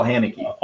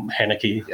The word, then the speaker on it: Haneke: Michelle Haneke's. – Oh, Haneke. – Haneke. – Yeah. – I think everybody goes through a period where they mispronounce his name.